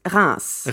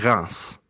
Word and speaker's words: Reims: What's the true correct French way to say it? – In French, it's Reims. – Reims.